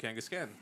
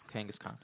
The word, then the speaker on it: Kangaskhan: I've pronounced it Kangaskhan. That's how we pronounce Pokemon in America. – Nah, okay. You're wrong. It's Kangaskhan. – Thank you. – Because it's K H A N.